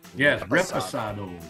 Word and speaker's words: Reposado: And I mispronounced that. I said Reposado. There's no S. It's Reposado.